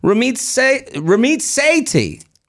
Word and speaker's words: Ramit Sethi: He is the author of I Will Teach You to Be Rich, Ramit Sethi. Am I saying it right? I'm sorry. – Yeah, you got it. You nailed it.